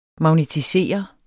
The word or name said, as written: magnetisere